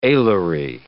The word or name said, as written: alary